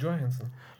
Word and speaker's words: Johansson: Immer Johansson, die heißt Johansson. – Das ist halt die englische Aussprache, aber sie ist ja äh, skandinavisch äh, von ihrer Sicher?